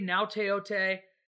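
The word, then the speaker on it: Gaoteote: Dan Hope of 11 Warriors writes today that Paulie Nauteote, you like that pronunciation? Had to go for the vernacular. I was proud of that. – That's amazing that you got that.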